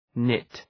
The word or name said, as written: nit